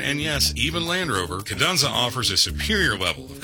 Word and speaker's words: Kadunza: Kadunza. – Kadunza? – Kadunzu. – Kadoozy? – It's Kadunza.